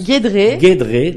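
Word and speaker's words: Guedré: Alors Guédré, c'est comme ça qu'on prononce? – Guédré, d'accord.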